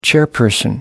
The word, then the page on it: chairperson: British English